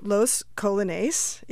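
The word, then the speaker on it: Los Colognes: They are called Los Colones, even though it's spelled like the colognes that you put on yourself when you're going out for a special date.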